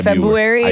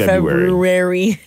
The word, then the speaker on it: February: Did you know the majority of people don't actually pronounce the R in February? – I'm the worst at that. I always say, you say February instead of February.